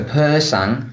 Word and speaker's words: Persang: And so, if we look at some of the other lions you've lion breded, I'm going to say this wrong, is it the Purisang or the Persang? – Okay, the Persang, yeah, that was a sonic thing.